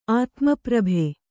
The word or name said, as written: ಆತ್ಮಪ್ರಭೆ